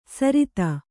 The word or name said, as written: ಸರಿತ